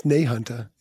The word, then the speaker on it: Nahunta: Not to be the pronunciation police, but it's Nahunta, isn't it? – Yes.